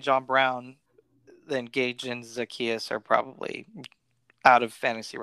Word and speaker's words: Zaccheaus: They're not happy with Russell Gage and Olamide Zaccheaus. I hope I'm saying that right. – You got the Zaccheaus part right, and that's all we care about.